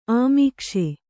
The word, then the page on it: ಅಮೀಕ್ಷೆ: kannada